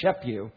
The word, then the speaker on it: Schaput: Watch for that name, S-C-H-A-P-U-T, but pronounced Shepue.